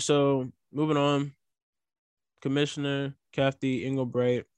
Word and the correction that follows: Engelbert: Did I say that right? – Engelbreit? It's Engelbert.